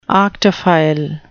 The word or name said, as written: arctophile